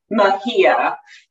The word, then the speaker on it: Mejia: But first off, my name is pronounced Kian Mejia. It's my married name. My husband's Colombian, so it's Spanish.